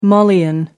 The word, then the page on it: Malian: American English